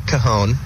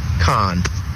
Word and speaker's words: Kahan: Not Kahan or Kahan or Kahan. – Oh. – It's Khan. – Oh. – One of the A's is silent. So, kind of like Genghis Khan, but not related at all.